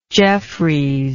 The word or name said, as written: jeffries